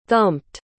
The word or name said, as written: thumped